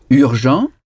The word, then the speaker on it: urgent: Urgent. Urgent. – Urgent. – Urgent. Urgent. Ça s'écrit de la même façon qu'en français, mais UR en anglais se prononce E. Ce mot se prononce urgent.